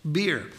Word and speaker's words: bier: Now, I looked it up. That's the way it's pronounced. It's bier. I wanted to say bier, but it's bier.